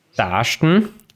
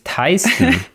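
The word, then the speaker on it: Taisten: Taschen ist die lokale Aussprache des Ortes Teisten.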